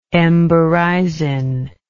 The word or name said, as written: emberizine